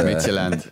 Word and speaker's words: Midtjylland: So we ended up drawing Liverpool, Atalanta, FC Midtjylland in Group D yeah, the, the pronunciation there is uh, Midtjylland.